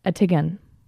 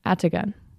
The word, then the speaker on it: Atigun: Please let me know if I'm pronouncing that right. Attigan. A-T-I-G-U-N. Attigan. Attigan. Attigan. Attigan Pass.